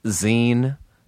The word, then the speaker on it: zine: It is called It's Pronounced Zine, spelled Z-I-N-E. Just a dumb joke, but the word is zine.